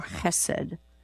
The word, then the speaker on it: chesed: And so we want you to have the sound of a het here and not just a plain H. Now, the book that we're offering, it spells it H-E-S-E-D, but it can be with a dot under it. Then people who know this rule will pronounce this with the ch sound for chesed.